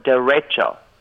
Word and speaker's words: derecho: And they taught me the word derecho. Hold on. – Excuse me? – Derecho. Yes, that's how you pronounce it.